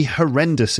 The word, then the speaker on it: horrendous: For example, getting sick in a foreign country can be an absolutely horrendous experience. Horrendous. Three syllables, and it's the second syllable. Ren. Horrendous.